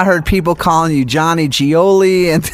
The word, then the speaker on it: Gioeli: But it's just simply pronounced Gioeli. If you say four letters, G-O-L-E, you say those four letters fast, G-O-L-E, you got it, Gioeli.